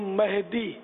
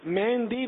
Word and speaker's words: مہدی: امام مہدی، مہندی نہیں، مہندی ہاتھ میں لگاتے ہیں، میں نے داڑھی میں لگائی ہے. مہدی میم ہا دال یا مہدی ہے، ویسے عربی تلفظ اس کا مہدی، میم ہا زبر مہ، لیکن اردو تلفظ بنے گا مہدی.